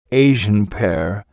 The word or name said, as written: Asian pear